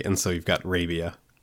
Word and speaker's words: Rabia: They pronounce it Rabia on the podcast, so I guess that's the way it's supposed to be said.